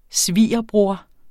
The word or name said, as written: svigerbror